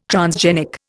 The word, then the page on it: transgenic: Indian